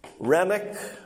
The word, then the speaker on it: Renwick: It's spelt R-E-N-W-I-C-K, but it's pronounced Rennick, so there's a silent W in there, all right? Whenever I first looked at it, I thought it was Renwick, but apparently the pronunciation is Rennick, okay?